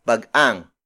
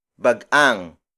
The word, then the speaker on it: bagang: This lesson is brought to you by Bag-ang. Bag-ang. Bag-ang. Bag-ang is spelled as B-A-G-A-N-G.